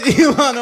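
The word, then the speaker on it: Ilhan: Elio Omar. How do I say that? – It's Elon Omar and Jesse Waters.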